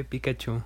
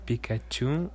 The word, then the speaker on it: Pikachu: Y que yo sepa es en, el primero, en Pikachu.